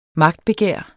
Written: magtbegær

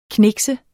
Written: knikse

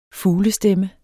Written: fuglestemme

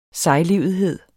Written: sejlivethed

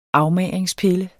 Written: afmagringspille

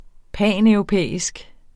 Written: paneuropæisk

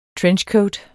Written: trenchcoat

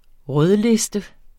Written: rødliste

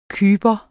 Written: kyper

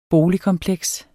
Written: BOLIGKOMPLEKS

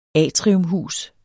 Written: atriumhus

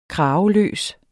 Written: kraveløs

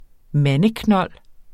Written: mandeknold

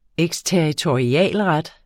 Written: eksterritorialret

